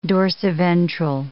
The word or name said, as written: dorsiventral